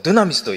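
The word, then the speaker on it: ド ゥ ナ ミ ス (δύναμις): えー、まずですね、この奇跡っていうのは、このギリシャ語で何て言うかって言ったら、こうドゥナミスと言います。この、あやっぱ発音いいですね。 – 一応進学校出てます。僕も出てるはずなんですけどね。一緒に言ってみましょうか。ドゥナミス、せーの。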